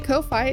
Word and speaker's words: Ko-fi: And I don't care what people say, Ko-Fi is the way you pronounce it.